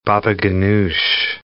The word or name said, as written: baba ghanoush